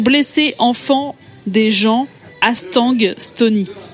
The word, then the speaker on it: Stony: Stony, ça s'écrit S-T-O-N-Y. Je m'en rends compte en le disant que ça se trouve, vous comprenez Estonie comme le pays.